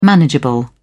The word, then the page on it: manageable: British English